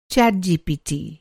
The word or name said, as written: चैटजीपीटी